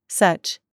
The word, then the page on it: CHURCH: American English